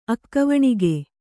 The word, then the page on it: ಅಕ್ಕವಣಿಗೆ: akkavaṇige